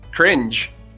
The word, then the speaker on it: cringe: Anyways, for those of you that stayed, enfim, para os que ficaram, a pronúncia tem esse j, cringe, por conta da letra G que é pronunciada G em inglês, sempre que anteceder as letras E ou I.